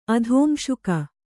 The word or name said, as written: ಅಧೋಂಶುಕ